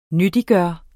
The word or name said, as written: nyttiggøre